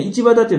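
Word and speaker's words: 一畑: ちなみにね、市畑っていうのは一つに畑と書いて市畑と読むそうですね。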